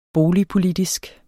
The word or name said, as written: boligpolitisk